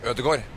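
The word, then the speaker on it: Ødegaard: How do you guys say Odegar? – Odegor. – Odegor. Odegor. We say Odegar. We're going to say Odegar is easier than Odegor, you know? – Say whatever you want.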